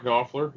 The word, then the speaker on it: Knopfler: Another person, Mike, I'm going to say this wrong, Knopfler, Dire Straits leader. – Oh, Mark Knopfler, yeah.